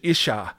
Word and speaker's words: Ish: Ish and Isha, I think, are the words that are used there. Ish and Isha, I think, is the better way to pronounce them.